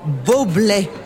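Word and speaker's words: Bublé: Arrête de jouer les imbéciles, Franck, c'est pas Bubble, c'est bubble. Prononcé Boblet, Michael de son prénom.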